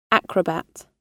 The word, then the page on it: acrobat: British English